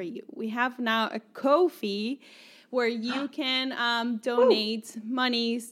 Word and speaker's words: Ko-fi: So you can go to Ko-fi, which is spelled K-O-F-I. Say it with me. – K-O-F-I.